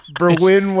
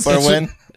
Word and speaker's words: Berwyn: Where did you mean to say Berlin and you say it like a dummy, or is it really Berwyn? Okay. – No, it's in Wales. – Berwyn, Wales. – Berwyn.